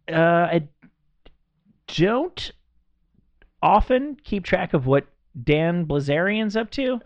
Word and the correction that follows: Bilzerian: I think I even say his name wrong. It's right. It's Bilzerian. – Is it? – It's not Blizzarian.